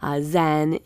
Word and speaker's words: Zen: Zen is the Japanese pronunciation of the Chinese word chan.